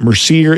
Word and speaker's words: Mercier: Just look at Mercier. It's spelled M-E-R-C-I-E-R, M-E-R-C-I-E-R.